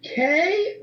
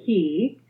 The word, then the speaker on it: Cay: Okay, so first of all, is it K or Key? – Well, that's a very good question. So the correct Bahamian pronunciation would be Key.